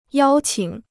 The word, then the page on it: 邀请: yāo qǐng